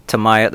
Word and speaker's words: Tamiya: So I ended up buying a Tamiya. That's how we say it in Brainerd. It can be pronounced about 10 different ways.